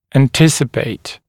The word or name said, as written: anticipate